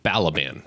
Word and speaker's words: Balaban: Balaban, right? Spelled just like it sounds. Rhymes with Taliban.